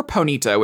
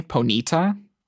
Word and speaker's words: Ponyta: I think the most important question here is, how do you pronounce? I have always said Ponyta. – Oh my God. – Where do you put the emphasis? – Like in the E, Ponita? – It sounds better that way. I've always said Ponita.